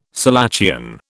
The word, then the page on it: selachian: British English